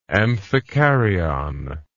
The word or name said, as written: amphikaryotic